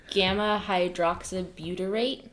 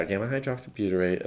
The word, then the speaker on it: gamma hydroxybutyrate: Gamma hydroxybutyrate. – Yeah, gamma hydroxybutyrate.